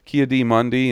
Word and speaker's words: Ki-Adi-Mundi: Kia Ki-Adi-Mundi. I almost said his name right there. Ki-Adi-Mundi and some other Jedi fucking shirtless practicing lightsaber.